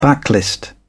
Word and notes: backlist: British English

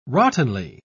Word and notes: rottenly: British English

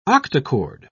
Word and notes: octachord: British English

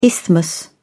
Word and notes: isthmus: British English

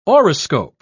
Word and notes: auriscope: British English

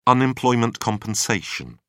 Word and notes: unemployment compensation: British English

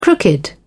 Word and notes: crooked: British English

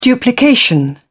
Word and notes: duplication: British English

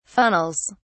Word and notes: funnels: British English

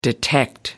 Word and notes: detect: American English

